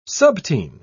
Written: subteen

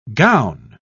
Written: gown

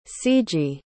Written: ciji

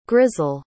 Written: grisel

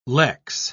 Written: lex

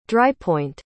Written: drypoint

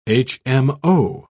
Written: hmo

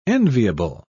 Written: enviable

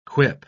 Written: quip

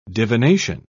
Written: divination

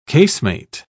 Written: casemate

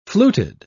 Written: fluted

